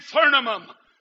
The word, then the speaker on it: Thummim: But the correct pronunciation is Urim and Thummim.